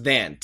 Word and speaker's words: than: The next word is than. T-H-A-N. And perfect pronunciation is than. But when Americans say it fast, it actually sounds more like than. Like T-H-E-N. But don't be confused. We are saying T-H-A-N. Harder than you think, T-H-I-N-K.